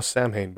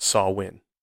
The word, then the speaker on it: Samhain: That's why I thought, oh, Samhain. But again, I didn't know any better. So doing some research, I found out it is pronounced Samhain.